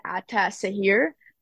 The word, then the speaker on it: Ataşehir: You can just say Ataşehir.